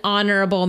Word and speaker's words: honorable: An honorable man. – Oh, that's right? That one. The H is silent, though.